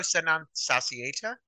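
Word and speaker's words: Sed Non Satiata: Ditro Senon Satieta, that's how you pronounce their name.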